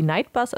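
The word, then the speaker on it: Knight Bus: Und was ich noch rausgefunden habe, was ich ganz lustig fand, der Nightbus im Englischen wird ja ausgesprochen wie Nightbus, also wie so ein normaler Nachtbus, wird aber mit K vorne geschrieben, also wie Night.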